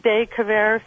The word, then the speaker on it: De Quervain: And for about 2 years now, I've had, I probably won't say it right, De Quervain syndrome.